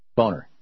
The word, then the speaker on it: Boehner: You mean John Boehner. I mean Boehner. Boehner. Yeah. – Phonetically, it's Boehner, but that's not what we're talking about now.